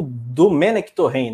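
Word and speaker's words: Domènec Torrent: É, você elogiou a escolha, mas o Dome, o Domenech Torren, né? Essa é a pronúncia.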